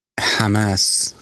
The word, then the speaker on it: Hamas: Somebody wrote us saying you're pronouncing Hamas wrong, and you're not, it's not hummus, and Hezbollah.